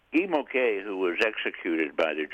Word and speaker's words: Guy Môquet: And the young man that you highlight in the first chapter of the book is one Guy Môquet. Is that how we pronounce his name? – Correct.